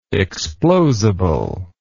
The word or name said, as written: explosibility